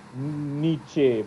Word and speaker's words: Nietzsche: So it's like Nietzsche, but...